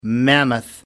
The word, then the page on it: mammoth: American English